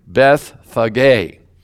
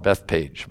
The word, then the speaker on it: Bethphage: In Matthew 21, verse 1, now when they drew near Jerusalem and came to, now this word, this name of this town is pronounced Bethphage. Bethphage, spelled B-E-T-H-P-H-A-G-E. I used to call it Bethpage, but it's Bethphage.